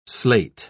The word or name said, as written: slate